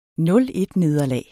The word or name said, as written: 0-1-nederlag